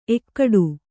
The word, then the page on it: ಎಕ್ಕಡಿ: ekkaḍi